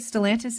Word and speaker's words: Stellantis: You represent three of Canada's biggest automakers Ford, GM, and the multinational Stellantis. Uh, tell us where, or is that right? Stellantis? Is that is that the correct pronunciation? – That's correct. Yeah, and that's uh, previously FCA Canada, so Chrysler.